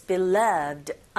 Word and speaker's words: beloved: 이 beloved 같은 경우에는 beloved가 아니라 beloved. 두 번째 위치에 강세가 있기 때문에 this와 쭉 한 덩어리처럼 this beloved 이렇게 들렸거든요.